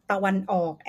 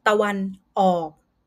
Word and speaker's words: ตะวันออก: Next word is also the direction ตะวันออก and ตะวันตก, east and west. I will pronounce it one by one. East, ตะวันออก, ตะวันออก, and west, ตะวันตก.